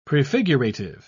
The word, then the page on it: prefigurative: British English